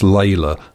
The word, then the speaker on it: Leila: The long suspected transitional form between Demis Roussos and Dr. Hook, spelt L E I L A, but pronounced Layla.